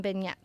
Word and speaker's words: beignets: And then for the third course, it's their orange blossom beignets. So beignets. – Beignets. I was working on my pronunciation.